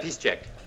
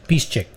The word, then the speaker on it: Piszczek: Schmelzer, Piszczek, is it how I pronounce his name? I forget his name. – Piszczek. – Piszczek.